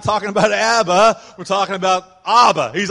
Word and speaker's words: Abba: This is Abba, pronounced Abba, not Abba.